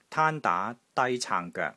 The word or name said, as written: TAAN1 DAA2 DAA2 CAANG3 GOEK3